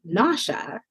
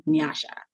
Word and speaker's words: Nyasha: So I decided to say Nyasha instead of Nyasha, which is how the correct pronunciation of my name.